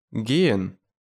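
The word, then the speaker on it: gehen: Например, пишется «ге-и-ха-и-н», а произносится «ген».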